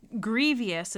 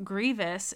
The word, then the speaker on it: grievous: And then this other question is like this idea of sin being glorified versus, I wrote down grievous is how I said it, but grievous is the way that it's pronounced.